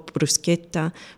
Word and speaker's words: bruschetta: No, but this, and I mean to, just because the K doesn't exist in the Italian alphabet, and the CH makes the K sound. So grigetto, bruschetta would be pronounced with that way.